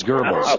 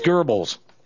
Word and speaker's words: Goebbels: It's Goebbels. – Okay. – It's Goebbels.